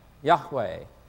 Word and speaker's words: Yahweh: When the vowels of Adonai were combined with the consonants of Y-H-W-H, you get Yahweh. Which is likely, which is the widely accepted among Bible scholars as the closest approximation of the original pronunciation of his name, Yahweh.